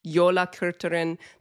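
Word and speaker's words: Jólakötturinn: So this family of Christmas terrors also has a cat, Jola Kurturin. The emphasis, she gave me this tip, the emphasis in Icelandic is always on the first syllable.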